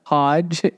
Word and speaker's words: Hoge: And his friend Charles Hoagie. – Well, the sandwich king of Kansas. – I'm sure it's pronounced Hodge, H-O-G-E, but I'm going to just pronounce it Hoagie.